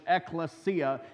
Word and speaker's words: ekklesia: Well, we're talking about the biblical word that we get the idea of church from, and that word is a Greek word called ekklesia and you pronounce it just like you see down, down, down there in English, ecclesia.